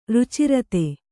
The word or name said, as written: ರುಚಿರತೆ